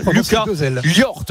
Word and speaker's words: Llort: Alors il était en conférence de presse hier, on en a vraiment profité pour lui demander comment se prononce son nom à ce joueur d'origine catalane qui était l'an dernier à Angoulême. Deux L ORT, ça Llort. – Lucas Llort.